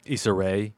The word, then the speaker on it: Issa Rae: Issa Rae.